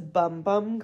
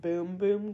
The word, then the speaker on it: Bum Bum: It looks like it says bum bum cream, but apparently it's pronounced boom boom cream. – But it's B-U-M. – Yeah, but it's like a Brazilian thing.